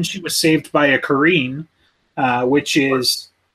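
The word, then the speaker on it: Qarin: No, if you look it up, I think it's Kareen, but it's Q A R I N.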